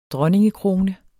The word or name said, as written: dronningekrone